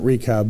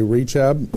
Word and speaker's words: Rechab: Well, I'm pronouncing it Rechab, but you might say Rechab. I like Rechab better.